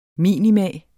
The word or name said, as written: minimum